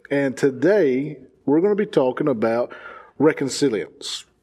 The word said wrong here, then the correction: reconciliation: I didn't say that right. – No. – Reconciliation.